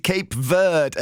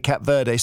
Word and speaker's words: Cape Verde: Now, you've got an insight into Cape Verde, as I'm told I must pronounce it. I always used to say Cap Verde, so I was totally wrong.